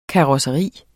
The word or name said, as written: karosseri